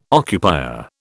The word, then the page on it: occupier: British English